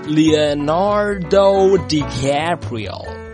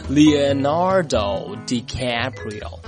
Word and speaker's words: Leonardo DiCaprio: 原来呀，是因为他的英文的原名呢是念作，来和我一起念，念作 Leonardo DiCaprio。再来一遍 ,Leonardo DiCaprio。